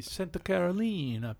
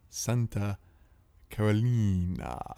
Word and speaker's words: Santa Carolina: 10% French oak in the Santa Carolina Pinot Noir. – No. You mispronounced it. Santa Carolina.